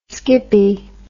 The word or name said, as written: skippy